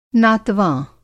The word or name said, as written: नातवाँ